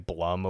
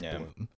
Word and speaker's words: Bloom: I like had just gotten into the habit of saying Blum over the years, because that's how I've like read it, but I'm pretty sure it's Bloom.